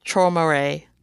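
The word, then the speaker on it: Träumerei: One day, she hears Junyoung playing Taumehe, which I think that's how you say it. It's in German, and I looked up the pronunciation, and I think I just butchered it anyways. But It's Traumere. – Traumere for the those who don't speak German like me.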